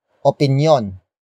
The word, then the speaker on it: opinyon: The main topic is a possessive phrase, starting with opinyon. Opinyon. Opinyon. Opinyon is spelled as O-P-I-N-Y-O-N.